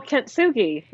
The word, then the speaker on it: Kintsugi: Is it K-I-N-T-S-U-G-I? – Oh, Kintsugi.